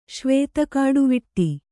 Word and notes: ಶ್ವೇತಕಾಡುವಿಟ್ಟಿ: kannada

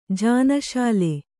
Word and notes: ಜಾನಶಾಲೆ: jānaśale

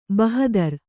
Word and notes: ಬಹುದಾರಿ: kannada

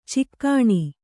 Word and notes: ಚುಕ್ಕಣ: cukkaṇa

